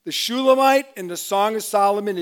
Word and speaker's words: Shulamite: Now the one who is the fairest among women is the Shulamite, and according to Strong's, even though I'm not going to obey him, it's pronounced Shulamith. But I like Shulamite better, so I'm going to stick with that.